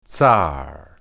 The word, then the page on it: tsar: hebrew